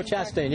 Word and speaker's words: Chastain: Because they sent, if I'm saying her name right, Jessica Christine out first from... Yeah, Chastain. – Oh, Chastain, yeah, okay. – Sorry, I said her name wrong.